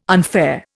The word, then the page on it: unfair: Indian